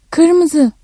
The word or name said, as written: kırmızı